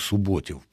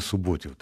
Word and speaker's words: Суботів: Я завжди думав Суботів. Потім я дізнався, що Суботів, а ви знову кажете Суботів. Так все ж таки, як? – Ну, наскільки мені відомо, має бути на передостанньому складі наголос, ну, а там...